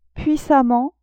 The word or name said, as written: puissamment